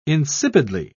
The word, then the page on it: insipidly: British English